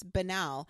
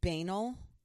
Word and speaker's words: banal: Oh, it's just it's banal. And she pronounced it banal. – I think I've heard it pronounced both ways. – I've heard it... apparently, both ways are acceptable.